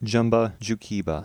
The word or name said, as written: Jumba Jookiba